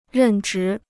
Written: 任职

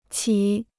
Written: 起

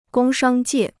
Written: 工商界

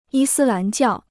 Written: 伊斯兰教

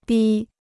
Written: 逼

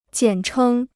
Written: 简称

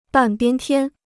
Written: 半边天

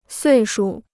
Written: 岁数

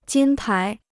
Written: 金牌

